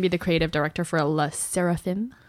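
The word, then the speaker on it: SSERAFIM: SSERRAFIM.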